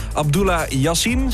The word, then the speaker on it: Abdullah Yassin: We hebben Abdullah Yassin, zeg ik dat goed? Helemaal goed, hoor ik al van de zijkant.